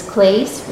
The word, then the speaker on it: Klase: Uh, we didn't want to use our last name, which is Klase, which begins with a K, which is always mispronounced. we are actually the Clauses.